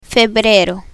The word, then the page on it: febrero: spanish